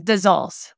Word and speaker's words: Dazols: Did I say that wrong? – Close enough. It's Dazols.